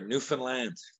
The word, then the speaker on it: Newfoundland: Now, living on the island and being from it, now, is it pronounced Newfoundland or Newfoundland? – Neither, Newfoundland.